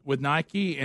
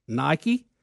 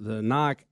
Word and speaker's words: Nike: Why can't this happen with Nike and the Muslims right now? – A lot of Muslims are unhappy. They say that Nike, or Nike as it's spelled